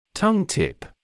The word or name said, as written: tongue-tip